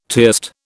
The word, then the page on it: tierced: British English